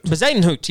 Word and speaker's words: Bezuidenhout: Bezuidenhout.